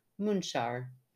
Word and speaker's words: Munxar: she gives me the pronunciation, Munxar, spelled Munxar. I always like to put the spelling in there because that just blows my mind that Munxar would be pronounced Munxar at St. Thomas Bay.